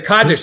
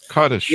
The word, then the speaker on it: Kaddish: And then there's the, I'm not sure if I pronounced this right, the Kaddish service? – Yeah, the Kaddish service. – Kaddish.